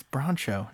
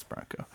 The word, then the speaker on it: Broncho: Not like the white Ford Bronco or like the horse it's named for, but like bronchitis with an O at the end. So it's spelled with a CH. – Ah. – But I, like Broncho. – Broncho, exactly. Oh, have I been pronouncing it wrong? It's Broncho. No, I'm just kidding. It's Bronco.